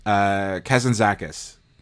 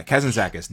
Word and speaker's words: Kazantzakis: I learned how to pronounce it. Kazan Kazantzakis. – Not going to work here. Anyway. – Yeah, Kazantzakis. Nikos Kazantzakis. – Yeah.